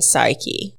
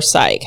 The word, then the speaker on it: Psyche: And it's the story of Cupid and Psyche, or Psyche, however you want to pronounce it.